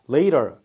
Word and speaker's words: later: 그리고 나중에란 뜻의 단어도 later, 이렇게 굴려서 이 T도 거의 그 roar 이런 발음에 가깝게 나오면서 끝에 그 R 발음도 들어가는 게 바로 또 미국식 발음이죠. later, 근데 later 이렇게 해가지고 T 발음을 정확하게 발음하고 끝에 R 발음을 거의 죽이는 발음, 그게 또한 영국식 발음이 됩니다.